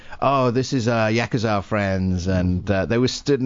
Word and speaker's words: Yakuza: Yakuza.